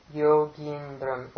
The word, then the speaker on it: Yogindram: I didn't make that E quite long enough. It's Govinda Yogindram. Long E. Okay. Govinda Yogindram. – Govinda Yogindram. – It's Yogi plus Indra, right?